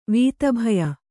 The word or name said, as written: ವೀತಭಯ